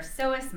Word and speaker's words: psoas: For example, we know that our psoas muscle, and by the way, I'm going to interject here, psoas is spelled P-S-O-A-S.